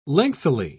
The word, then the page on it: lengthily: British English